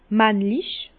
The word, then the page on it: männlich: Bas-Rhin; Herrlisheim